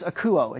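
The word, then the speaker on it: akouo (ἀκούω): And the first Greek lesson that you're going to get today is the Greek word to hear, which is akouo. It kind of sounds like the word acoustic, okay?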